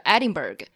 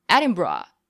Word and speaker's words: Edinburgh: 就是美式发音的角度讲会说是 Edinburgh。 – 嗯，但是当时那个就是 Edinburgh 就是他当地的那个说法。